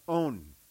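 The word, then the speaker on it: own (ὤν): In Greek, it's pronounced own. O-N in English. Own, long O. Own.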